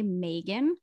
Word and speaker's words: Magen: Anytime it has some A in it, and you know why I think that is because my name is spelled M-A-G-E-N, and I always say the long A, Megan.